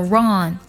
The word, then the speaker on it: wrong: 第二组呢，就是 w r 开头，w 不发音，比如说 write 书写那个单词，还有 wrong 错误这个单词都是这样的。